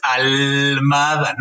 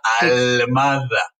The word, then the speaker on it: Almada: Y si es usted una persona distraída, sepa que el apellido es Almada, no Aldama, no Ahumada, no Almohada, Almada.